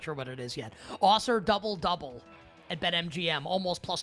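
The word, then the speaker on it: Ausar: Is that how you pronounce his name, by the way? It is, right? Osser Thompson? – Yeah, it's Amen and Osser, I think. – Osser.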